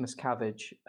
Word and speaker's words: Miscavige: Maybe you can email David Miskovich and say that you work in environmental engineering and that you can come around and record John, his noisy wife. – John, I believe it's pronounced Miscavige, and I'm not about to get into a lawsuit.